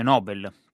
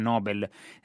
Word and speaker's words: Nobel: A telefono dalla sua casa a nord di Manhattan, scrive Alessandra Farkas sul Corriere della Sera, Toni Morrison, premio Nobel per la letteratura nel 1993, tra l'altro è stato l'ultimo premio Nobel degli Stati Uniti, o Nobel a seconda di come lo volete pronunciare,